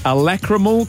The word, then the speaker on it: lacrimal: Lacrimical carungles. – That is not how you say it, I'm sure. – Caruncle. – What? – A lacrimal caruncle.